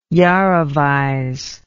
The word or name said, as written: iarovize